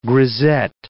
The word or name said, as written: grisette